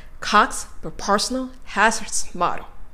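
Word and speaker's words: Cox proportional hazards model: Cox, proportional, hazards, model. Cox, proportional, hazards, model. という大きな 4 つの山が描かれるように発音されているのが聞こえたのではないかと思います。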